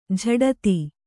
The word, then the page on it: ಜಡಿತ: kannada